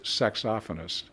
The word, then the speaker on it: saxophonist: By the way, it's pronounced, and this you can look this up online. I was corrected about a year ago. – Oh, okay. – It's pronounced saxophonist. I'm not kidding you. That sounds like a really weird thing, but it is true.